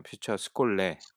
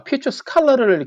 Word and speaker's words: 퓨처 스콜레: 그러면 그렇게 시즌 3를 하고 있고 1인 기업을 하시다가 퓨처 아까 제가 말씀을 발음을 잘못 드렸네요. 퓨처 스콜레. 네. – 네. – 근데 저도 여쭤보고 싶었는데 저도 아 퓨처 스칼러를 이렇게 발음 잘못 쓴 건가 싶어서 봤더니 그냥 퓨처 스콜레더라고요.